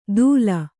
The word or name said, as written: ಧೂಲ